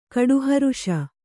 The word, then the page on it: ಕೋಟಿಹೊರಿಸು: kōṭī horisu